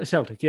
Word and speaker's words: Celtic: Like you mentioned with the is it Celtic? Is that what it is? The pronunciation is a Celtic. I don't know. – Celtic. Yeah. The Glasgow team.